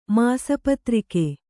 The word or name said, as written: ಮಾಸಪತ್ರಿಕೆ